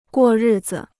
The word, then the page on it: 过日子: guò rì zi